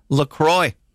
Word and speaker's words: LaCroix: Officially, it's pronounced LaCroix.